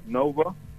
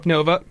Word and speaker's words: NXVA: So NXVA, that says yep. – Nova. – Okay. – That's pronounced as Nova. – Nova.